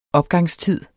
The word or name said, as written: opgangstid